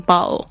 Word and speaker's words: bottle: 但是呢我也发现有一些英式发音他们是不会发 T 这个声音，他们就会 bottle, 你就只会听到 B-O 跟这个 L-E 的声音 bottle。